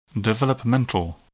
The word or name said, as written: developmental